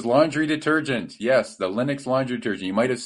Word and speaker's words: Linux: I pronounced it Linux, just be like Richard Stallman there.